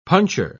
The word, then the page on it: puncher: British English